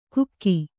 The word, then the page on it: ಕುಕ್ಕಿ: Kannada